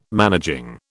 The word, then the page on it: managing: British English